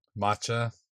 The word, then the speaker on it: matcha: Matcha or am I saying it wrong? – Matcha. – Matcha, yes.